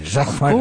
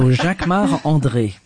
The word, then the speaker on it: Jacquemart André: Jacquemart André. – Of course, because I'm French. So Jacquemart André. – And say it so we can understand it. – Jacquemart André.